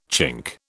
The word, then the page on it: Chink: British English